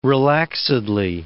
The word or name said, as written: relaxedly